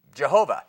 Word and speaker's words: Jehovah: It's long been questioned, what does the YHWH, not what does, how do we pronounce that? Now, Americans say Jehovah. Yehu is another one. Yahweh is another one. Yivi, Jehu, Yahu, Yahu. Jahio and Jove are all just as good names as pronunciations as Jehovah for the YHWH.